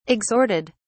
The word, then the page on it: exhorted: British English